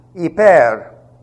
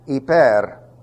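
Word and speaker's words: Hiper (ὑπέρ): Hiper. Now I'm going to translate that for you. I'll translate it the way they do. H-Y-P-E-R. Hiper. And if you can't roll your R's, hiper.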